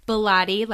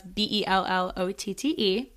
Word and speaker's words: Bellotte: Bellotte, like Pilates. I always say that because everyone always pronounces it wrong. So that's Bellotte.